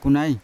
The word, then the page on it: kunai: Yawa